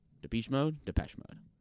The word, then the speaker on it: Depeche Mode: Depeche Mode.